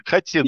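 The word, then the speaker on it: Хотин: Вот ты упомянул турецкую крепость Хатин. Там ударение на второй слог. Это точно, я в свое время об этом уже свой нос уже, да, расквасил. Хатин, да.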